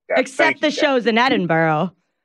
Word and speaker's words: Edinburgh: Edinburgh, thank you.